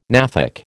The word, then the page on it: gnathic: American English